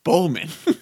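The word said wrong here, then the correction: Boseman: Bozeman. – Yep. There we go.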